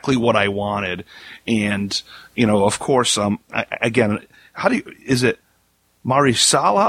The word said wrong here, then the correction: Mahershala: Ali? Is that how you pronounce Mahershala. – Mahershala Ali. Yep.